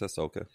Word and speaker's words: Ahsoka: Take the H out. It's Ahsoka. – Ahsoka. You could see her training him at some point in between the Mandalorian and true. So do you think Ahsoka series is going to come in the timeline after The Mandalorian? – I would imagine.